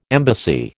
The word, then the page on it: embassy: American English